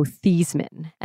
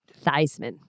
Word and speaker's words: Theismann: And that's because of Joe Montana and Joe Theismann, who, incidentally, when he was growing up in South River, New Jersey, his name was pronounced Joe Theismann. And it's when he went to Notre Dame and they wanted his name to rhyme with Heisman that it became Theismann.